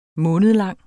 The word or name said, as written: månedlang